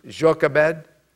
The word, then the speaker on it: Jochebed: Her name, later on we learn that her name was Jochebed. Some people pronounce it Jochebed.